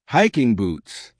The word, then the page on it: hiking boots: American English